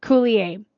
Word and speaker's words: Coulier: Coulier? – Coulier.